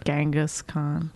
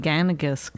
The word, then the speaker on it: Genghis Khan: Well, no one pronounced it like that. – How did they pronounce it? – Genghis Khan. – That's what I just said. – You were saying Ganagus. – I said Genghis Khan.